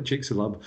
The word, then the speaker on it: Chicxulub: I might not be pronouncing it properly. We all call it Chicxulub.